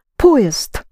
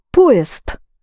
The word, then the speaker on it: поезд: Поезд. You can clearly hear east there, yes? Think of a train going east. Поезд. And the по is accented. You actually spell it поезд, but pronounce поезд.